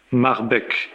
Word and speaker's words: Marbeck: Et il faudrait citer, en 1442, un certain Pilgram Marbeck, P-I-L-G-R-A-2-M, Marbeck, comme ça se prononce avec CK au bout, ça,